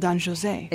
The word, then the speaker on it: Don José: You asked why I mispronounced Don Jose. Right? It's into a cultural thing here. Bizet is French. And the opera is in French. – And the opera is in French, not in Spanish. – That's why he's called Don José.